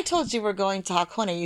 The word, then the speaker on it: Hakone: So H-I is Hikone, Hakone is H-A, and Heikone is H-E.